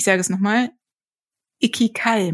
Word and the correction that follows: Ikigai: machen. War das richtig ausgesprochen? Ikigai. – Ikigai.